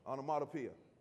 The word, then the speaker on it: onomatopoeia: Some of you who are English scholars have heard this word, but this word is what's called an onomatopoetic word. Hopefully I got that right. Onomatopoetic. Onomatopoeia. All right, okay. All right, we got. Thanks for straightening me out.